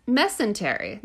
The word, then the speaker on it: mesentery: Mesentery. That sounds right.